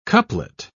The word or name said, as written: couplet